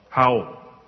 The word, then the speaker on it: hwyl: It's spelled H-W-Y-L. Howl. Okay, good. That was a good guess.